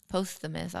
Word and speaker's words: posthumous: France is one of the few countries that covers post-mortis. – Can I see the word? Posthumous? – No. Let me see. Where are we at? Posthumous? Marriage. Whatever. – Posthumous. I was saying it wrong.